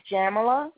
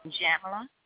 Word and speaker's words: Jamila: It's Jamila. It rhymes with Pamela, except for it's a J. – Jamila? Tamala? – Jamila.